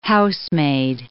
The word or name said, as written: housemaid